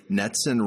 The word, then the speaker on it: NETCCN: First and most importantly, NETCCN rhymes with Jetson.